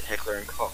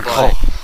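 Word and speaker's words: Heckler & Koch: So, if you go to Germany and you ask someone about H&K and how they pronounce it, they will pronounce it Heckler and Koch. – Heckler and Koch. – Koch, right.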